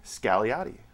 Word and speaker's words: Scagliotti: How do you pronounce the name? – Scagliotti. – There's no G.